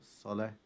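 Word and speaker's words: الصلاة: موضوع الصلاه انها مكتوبه بالواو وهي بتتنطق الصلاه ايوه هنا الالف فوق الواو معناته ان الواو لا تنطق الالف هو اللي هينطق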